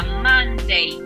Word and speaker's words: Monday: Monday, pero con la A súper abierta. Monday, igual que London.